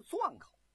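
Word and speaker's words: 沌口: 说这阵啊，这个陶侃啊坐镇在这个钻口，这个钻口是在哪儿？就是现在湖北啊汉阳的西南。其实这个钻呢是个多音字，也是混沌的顿字，就是三点水啊，左边啊三点水，右边一个屯字，村屯的屯字，这个字啊念钻。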